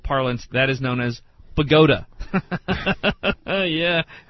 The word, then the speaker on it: Bogota: it's spelled Bogota, and if you weren't living in a dumb state, you'd call that Bogota, because that's what it was named for.